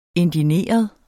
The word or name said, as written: indigneret